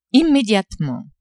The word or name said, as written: immediatement